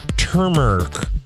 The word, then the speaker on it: turmeric: Turmeric. That's turmeric for those of us who don't like to pronounce the R.